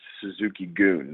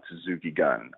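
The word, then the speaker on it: Suzuki-gun: It's Suzuki Goon, not Suzuki Gunn. I know it's spelled G-U-N like gun, like we pronounce, but it's actually Goon, and Goon translates to army.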